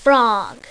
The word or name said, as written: frog